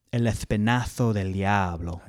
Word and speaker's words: El Espinazo del Diablo: It's actually pronounced in España, El Espinazo del Diablo.